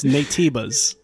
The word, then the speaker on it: Nativus: We play as Eve, a warrior rising up to defeat the Nativus, right? Nativus? – I like how I actually broke down the pronunciation on the docket, and I still messed it up. It's Natibas. – Natibas. Yeah. That's right.